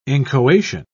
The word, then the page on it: inchoation: British English